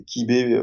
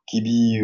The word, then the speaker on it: Quibe: c'est Kibé, enfin, Kibi ou, ou Quib, comme vous voulez, parce que ça se prononce comme on veut, .fr.